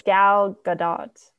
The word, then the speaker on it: Gal Gadot: How do you say it? – I think Gail Gadot. – Okay. I've been saying it wrong. I thought it was Gal Gadot this whole time. Oops. Whoopsies. Sorry, Gail Gadot. – No, I think it's Gal Gadot.